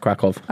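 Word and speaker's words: Kraków: Yeah, it's spelt with a Y, but it's pronounced Krakow.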